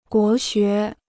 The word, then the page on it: 国学: guó xué